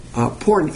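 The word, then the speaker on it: Porne (πόρνη): Now here the word for prostitutes is porne. Porne, actually, is how you should pronounce it. Porne, where we get the word pornography. P-O-R-N-E with a bar across the E, which is a hard E.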